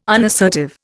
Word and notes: unassertive: Indian

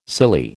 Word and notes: silly: American English

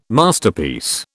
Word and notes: Masterpiece: British English

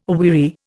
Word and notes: aweary: Indian

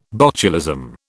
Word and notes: botulism: British English

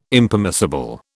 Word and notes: Impermissible: British English

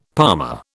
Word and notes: palmer: British English

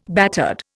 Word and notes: battered: Indian

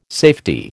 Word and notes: safety: American English

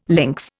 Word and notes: lynx: Indian

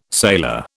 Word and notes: sailor: British English

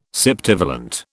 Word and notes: septivalent: British English

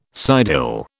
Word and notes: sidehill: British English

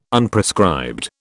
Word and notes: Unprescribed: British English

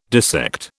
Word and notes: dissect: British English

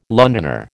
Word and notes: Londoner: American English

